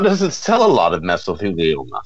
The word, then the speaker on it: mesothelioma: Mesothelioma.